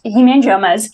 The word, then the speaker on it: hemangiomas: And can you also explain what hemangiomas are and to let me know if I'm saying it right. – Yeah, you're saying it right. Yeah, so hemangiomas.